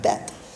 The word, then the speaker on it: Beth: It's spelled Beth, but it's pronounced almost like a bet, but it's a little softer than that, Beth.